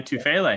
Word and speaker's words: Tufele: And then another guy that I've really enjoyed seeing, you know, on tape here is, I'll butcher the name, but Jay Tufele. – You did a good job, Ryan. Tufele.